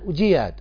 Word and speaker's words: أجياد: رآه في جياد، وأجياد بنطقنا الآن ينطقونها أهل مكة أجياد، وهي تنطق أجياد وجياد.